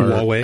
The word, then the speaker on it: Huawei: is it Highway? I don't know how to say the name of the company, the Android developer. They apparently are Huawei.